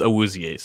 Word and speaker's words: Awuzie: The pronunciation is Awuzie, so okay.